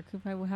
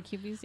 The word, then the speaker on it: Koopies: Are you talking about, like, the Koopies or Koopai? How do you pronounce it? – Well, Koopies are. – Yeah, Koopies, yeah.